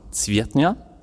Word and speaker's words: Zwiertnia: Eigentlich heißt er Mark Zwiertnia.